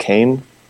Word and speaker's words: Qaim: And the citation, if you want to look it up further, is Klumper, K-L-U, with, you know, the umlaut. M-P-E-R, and I believe it's pronounced Kaim, Q-A-I-M.